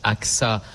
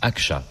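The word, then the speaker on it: Ağca: Alors, ça se prononce Aksa en français, mais en turc, Akça, puisqu'il était turc.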